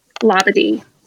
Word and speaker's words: Labadee: And then we went to Labadee. Am I saying that correctly? I hear people say it differently. – You are, so.